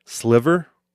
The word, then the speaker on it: Sliver: So I recorded a podcast with Zach Sliver. I think that's how you say his name. And whenever I see it, I think it's Silver. But it's spelled S-L-I-V-E-R.